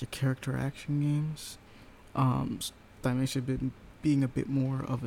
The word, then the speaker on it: Thymesia: Thymesia. – Mm-hmm. – I'd spell that, please. – T-H-Y... M-E-S-I-A.